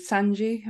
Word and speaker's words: Sanji: But him and, is it Sanji? Am I saying that right? – Yeah. – Yeah.